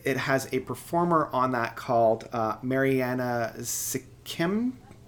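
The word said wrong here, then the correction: Semkina: I can never pronounce her last name. It's Mariana I'm going to actually look it up so I can... Mariana Semkinya. And the two sound eerily familiar, whereas I found like Yvette Young was much more of a complex jazz math.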